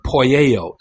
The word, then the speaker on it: poieo: In 1 John 3, 9, the word "practices," that verb "practices," is the Greek verb "poieo," that's spelled P-O-I-E-O.